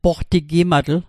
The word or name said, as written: poqtigimatl